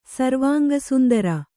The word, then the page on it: ಸರ್ವಾಂಗಸುಂದರ: sarvānga sundara